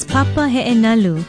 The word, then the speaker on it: papa he'enalu: Our Hawaiian word of the day is papa he'enalu.